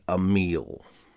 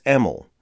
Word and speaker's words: Emil: I say Emil. Haury's daughter saw it and emailed me graciously thanking me for honoring her father's work. She also politely told me that I was saying his name wrong. I couldn't change that, but I can do better here. So I want to be clear it's Emil, not Emil.